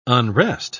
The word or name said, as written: unrest